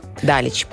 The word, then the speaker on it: Далич: Говорят, что у балканских, в балканских странах ударение на первый слог. – Далич.